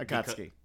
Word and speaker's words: Akatsuki: Akatsuki.